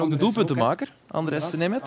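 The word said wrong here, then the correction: András: Andras. Andras moet je zeggen. Ook wel goed gedaan. – Andras in het eh, Hongaars, zo moet je het uitspreken.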